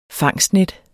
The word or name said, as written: fangstnet